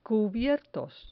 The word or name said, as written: cubiertos